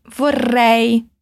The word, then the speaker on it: vorrei: Quando eu pedir para repetir vorrei, na hora de pronunciar os R's, coloca a língua lá no céu da boca, vibrando ela. Sabe quando o Kiko do Chaves vai lá no cantinho chorar, tipo brrr? – É mais ou menos isso.